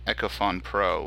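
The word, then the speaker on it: Echofon: I'm going to go with, I believe it was Echophone Pro, which was like $2.99. – You call it Echophone also? – We talked about this last week. It is spelled Fon, F-O-N, Fon.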